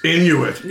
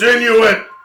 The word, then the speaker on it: Inuit: Oh, it's Inuit. – Yes, it is. – You thought she said I knew it? – No, I thought pretty sure in French it's pronounced in we. Okay. It's Inuit.